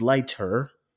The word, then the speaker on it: Leiter: His peace is short lived when Felix Leiter, hope I said that right.